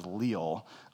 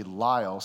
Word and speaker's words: Liele: Now, for the longest time, I pronounced it as Liele, but it's actually Liele.